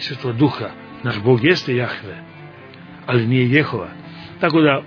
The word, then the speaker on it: Jahve: Prije svega Bogu nije ime Jehova, nego Jahve. To je pogrešno čitanje jevrejskog imena Božijeg.